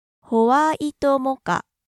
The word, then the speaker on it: ホワイトモカ: That's what I always order here in Japan, and notice the pronunciation starts with ho. – ホワイトモカ.